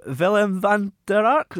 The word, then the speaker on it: Willem van der Ark: I hear you've got one coming out about Willem van der Ark. Am I saying that right? – Yeah.